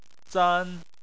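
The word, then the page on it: zan: tone 1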